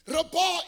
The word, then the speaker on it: rabah: The interesting, the Hebrew word used for multiply is the Hebrew word rabah. I think it's spelled R-A-B-A-H, but it's rabah, or R-A-W-B-A-W is how you pronounce it.